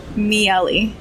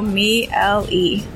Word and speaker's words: Miele: How do you say your last name, Daniel? – Miele. So Miele.